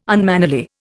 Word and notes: unmannerly: Indian

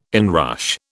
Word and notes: inrush: British English

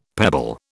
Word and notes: pebble: British English

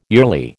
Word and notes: yearly: American English

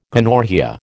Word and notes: gonorrhoea: American English

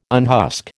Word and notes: unhusk: American English

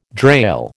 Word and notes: drail: American English

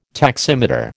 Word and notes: taximeter: American English

